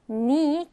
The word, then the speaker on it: knee: 자, 이때 아래 보면 지금 K는 묵음으로 소리가 나지 않는다라고 하죠. knee, K로 시작을 하지만 K는 발음하지 않아요. K는 그냥 묵음으로 처리하고 knee라고 발음합니다.